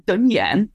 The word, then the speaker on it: đơn giản: And they'll say, yeah, Dun Yan. And I say, I know that's the southern pronunciation. I just prefer the way it's said in the north.